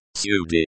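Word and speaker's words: Sudi (ਸਾਡੀ): Sadi. Is pronounced as Sudi and Vich. As Vich.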